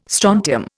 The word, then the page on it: strontium: Indian